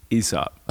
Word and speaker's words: Aesop: You just put me on blast because it's that Latin diphthong with the A and the E, like Caesar. So if you pronounce it Caesar, then then go right ahead with your Aesop.